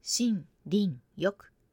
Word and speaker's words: 森林浴: この森、林、浴、 3 つの漢字を使って、森、林、浴と発音します。